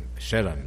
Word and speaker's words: Sheeran: Você ouviu aí o Ed Sheeran, Sheeran, né? É essa, a pronúncia é essa mesmo.